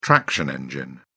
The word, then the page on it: traction engine: British English